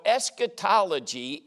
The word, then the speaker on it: eschatology: It's called eschatology. Now, let me spell that. That's a big old 50-cent word. E-S-C-H-A-T-O-L-O-G-Y. Eschatology.